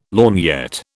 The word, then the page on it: lorgnette: British English